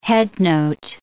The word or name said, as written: headnote